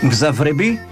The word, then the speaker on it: Mgzavrebi: Uma banda de Tbilisi com um nome de difícil de pronunciar, tem aqui muitas consoantes, eu vou arriscar. Mgzavrebi? – Exatamente. A palavra Mgzavroba é viajar, Mgzavrebi é viajante.